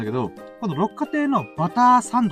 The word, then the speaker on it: 六花亭: 六花亭、六に花と書いて、あの、六花亭。